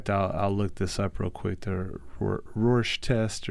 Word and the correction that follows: Rorschach: Um, Rorschach tests.